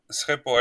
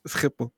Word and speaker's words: Schiphol: This is the first time I hear it properly pronounced. – Schiphol Airport. – Schiphol. It's like, I think it's one of the easiest pronunciations for Arabs. Or maybe I overdo it with the kha, right? Schiphol. – No, your is pretty Dutch. Schiphol. – Nice. Nailed it.